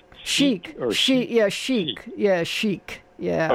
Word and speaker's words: chic: I mean, the way it's spelled, it looks like chick, but it's chic.